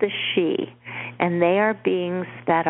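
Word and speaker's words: Sidhe: There's all these books about interactions with these people, but it's pronounced she.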